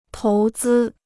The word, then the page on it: 投资: tóu zī